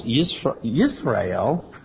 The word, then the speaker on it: Israel: Well, Jezreel, pronounced Yitzra'el, as opposed to Israel, pronounced Yisrael, comes from the Hebrew words Zara and El.